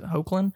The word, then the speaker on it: Hoechlin: I'm going to just hope I pronounce it Hoechlin. – Yeah.